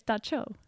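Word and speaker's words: dab tsog: And I, yep, it's Da Cho. The Cho. – The Cho. – And it's spelled, it's D-A-B-T-S-O-G. I had to look up how to pronounce. Yeah, it's not, you can't phonetically pronounce.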